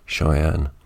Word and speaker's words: Shy-Anne: I should mention that her name is spelled S-H-Y hyphen A-N-N-E, Cheyenne.